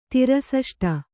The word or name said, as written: ತಿರಸಷ್ಟ